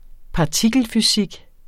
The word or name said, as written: partikelfysik